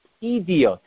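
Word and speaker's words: Eduyot: It's the best of the Mesechta, and therefore it should be pronounced Idiot.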